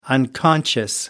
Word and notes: unconscious: American English

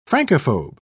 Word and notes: francophobe: British English

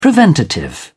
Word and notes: preventative: British English

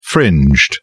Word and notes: fringed: British English

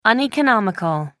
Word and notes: uneconomical: American English

